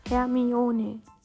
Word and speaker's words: Hermione: Im vierten Band erklärt Rowling die richtige Aussprache, indem sie Hermine ihren Namen langsam Victor Krumm vorsprechen lässt. Herr, mi, o, ne. Hermione. Hermione. Habe ich also vorhin auch falsch ausgesprochen. Perfekt. Also Hermione und nicht Hermione.